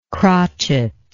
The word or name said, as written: crotchet